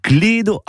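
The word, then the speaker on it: cledo: En réalité, ce mot vient de l'occitan clédo, avec un E fermé, mais prononcé à la française, ben ça donne clède.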